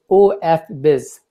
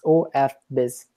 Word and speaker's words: OFBiz: So we pronounce it as OFBiz. – All right. – When David and Andrew Zanetsky give the name, so it is open for business. It's OFBiz. So we pronounce it like this.